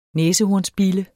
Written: næsehornsbille

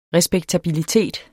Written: respektabilitet

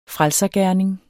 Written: frelsergerning

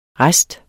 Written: rest

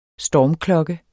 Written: stormklokke

